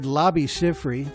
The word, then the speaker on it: Labi Siffre: Out of that, we heard Labi Siffre. That's Labi spelled L-A-B-I. Siffre with an S-I-F-F-R-I. You know, that's how you say it.